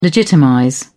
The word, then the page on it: legitimize: British English